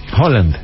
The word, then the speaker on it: Haaland: Y el señor Haaland, ¿cómo se dice? – Haaland.